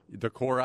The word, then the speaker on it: Decorah: It's Decorah, right? – Decorah, Iowa. You bet.